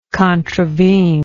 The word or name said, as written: contravener